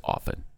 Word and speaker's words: often: You don't pronounce the T in often. It's often, not often.